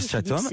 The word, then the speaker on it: striatum: Il montre le cortex et aussi une autre partie, ça s'appelle le striatum. Je prononce bien ? – Oui. Vous avez vraiment tout compris. – Le striatum. – C'est vraiment ça.